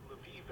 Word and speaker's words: Lviv: Or Lviv, as it's pronounced in Ukrainian, is a lovely little city nestled in the hills of western Ukraine.